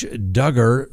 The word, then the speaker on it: Duggar: Next, Josh Duggar, rhymes with hugger.